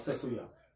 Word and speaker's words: Tetsuya: Say it like Tetsuo. – Yes. No. Tetsuya. – No, I'm Tetsuya. – There you go.